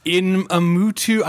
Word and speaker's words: Iñárritu: Alejandro González Iñárritu.